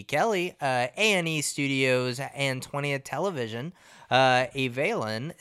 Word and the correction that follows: Avalon: is that how you say it? – Avalon, which has a straight-to-series order, takes place in the city Avalon on Catalina Island, where L.A.